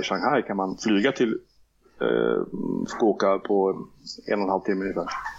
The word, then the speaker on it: Fukuoka: Vi fortsätter till Fukuoka, om jag uttalat rätt. – Fukuoka. Ja.